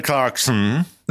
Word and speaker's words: Clarkson: Ale tak, zaakcentowałem to Clarkson zupełnie niepotrzebnie. Słyszałem. E, Clarkson.